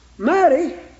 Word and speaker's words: Mattie: M-A-T-T-I-E and pronounced Maddie.